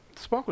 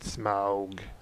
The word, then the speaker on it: Smaug: S-M-A-U-G. – A-U-G. A-U-G. Because people like to pronounce it Smaug. – Smaug. – It's not Smaug.